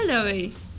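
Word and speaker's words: Laufey: Pero se escribe Lovey otra vez, pero se pronuncia Lovey. Bueno, es lo que ella dice.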